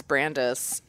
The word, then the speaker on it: Brandis: My real name is Brandis. And it's funny because like growing up, your teachers mispronounce Brandis and Posey get mispronounced constantly. So I'd get like Brandice Pose.